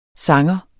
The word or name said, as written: sanger